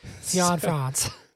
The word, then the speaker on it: Cianfrance: We get Derek Clanfrance. – Cianfrance. – So Derek Cianfrance. I don't know if I'm pronouncing that right either, but it sounds closer.